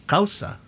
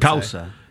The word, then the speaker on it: Cauca: How do you say C-A-U-C-A, in Espanol? Causa, I would Causa.